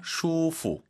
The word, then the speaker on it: 舒服: 아, 발음이 좀 쉽지 않아요. 슈프 아닙니다. 슈프. – 슈프. – 원, 투, 쓰리, 포 할 때 포 생각하시면서 슈프. 도움이 되십니까, 여러분?